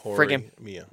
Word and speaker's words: Horimiya: Because one of the main characters is Miyamura, and the other character is Hori, and it's their names. – Horimiya.